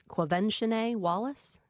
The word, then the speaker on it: Quvenzhané: And her name is Quvenzhané Wallace.